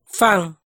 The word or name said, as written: faaj